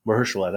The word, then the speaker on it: Mahershala: How do you say it? – Mahershala? – Mahershala. That's how you say it.